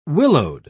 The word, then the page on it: willowed: British English